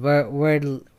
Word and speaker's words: Wordle: In the past week or so, there was a word game called Wordle, or Wordle, or how do you pronounce it? Wordle. You gotta say it like, you know, Wordle.